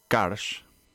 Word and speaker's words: Karsh: Quería empezar yo con Yusuf Karsh. Voy a deletrear su nombre y su apellido por mis típicos problemas de pronunciación precisa. Y-O-U-S-U-F, Yusuf, y el apellido es K-A-R-S-H. Karsh, Yusuf Karsh,